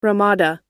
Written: Ramada

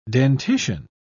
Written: dentition

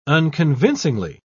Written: unconvincingly

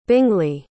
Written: bingley

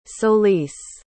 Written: solis

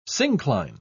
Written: syncline

